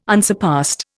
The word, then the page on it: unsurpassed: Indian